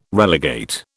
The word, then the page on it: relegate: British English